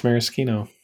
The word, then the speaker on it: maraschino: Today I am learning that it's pronounced maraschino.